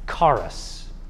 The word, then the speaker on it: charis: In English, you would write it C-H, with a hard C-H, like a German Bach. C-H-A-R-I-S. Charis.